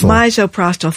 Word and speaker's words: Misoprostol: Misoprostol.